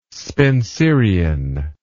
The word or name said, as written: spencerian